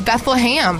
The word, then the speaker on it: Bethlehem: But I'm telling you, it's Beth-LUH-HAM.